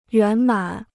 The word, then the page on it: 圆满: yuán mǎn